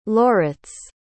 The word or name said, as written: Loritz